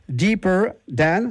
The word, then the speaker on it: Deeper Than: Merci de votre présence pour un groupe qui n'est pas un duo d'ailleurs, qui s'appelle Deeper Than, c'est comme ça qu'on prononce ? – Yes. – Bon. – Yes, c'est ça, on est bien.